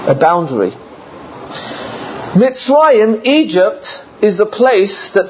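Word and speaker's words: Mitzrayim (מצרים): The name for Egypt is Mitzrayim. Mitzrayim is spelled Mem, Sadi, Resh, Yud, Mem.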